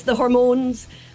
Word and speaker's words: Hormones: The Hamones, it's spelt hormones, but is it a kind of tribute to the Ramones, the way you pronounce it? – Yeah, it's the hormones. So we've got a capital M in there to help people know how to pronounce it.